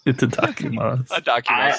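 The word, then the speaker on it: itadakimasu: Oh, but at the end you say itikimasu. – It's a dakimasu.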